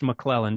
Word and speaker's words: McClellan: It's pronounced McClellan, just like my last name, but it's spelled McLellan.